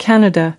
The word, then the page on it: Canada: American English